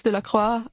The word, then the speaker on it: Delacroix: Korrekt französisch müsste es Delacroix heißen.